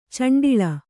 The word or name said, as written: ಚುಂಡಿಲಿ